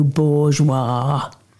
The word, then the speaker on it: bourgeois: Now, Nadezhda, I never thought you would be so bourgeois. He puts the emphasis on the last syllable, war.